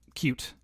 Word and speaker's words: Qt: And I think it's pronounced cute is how I think.